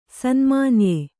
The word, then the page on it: ಸನ್ಮಾನ್ಯ: sanymānya